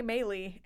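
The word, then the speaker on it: Meli: Maley.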